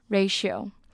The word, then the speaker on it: ratio: 那这个字的发音很特别，因为其实大家第一眼看到这个字可能会想要发成什么 ratio 或者是可能其他的音，因为其实我第一眼看到这个字我也不太会发，可这个字的发音呢 ti 这边发 sh 的音 ratio ratio。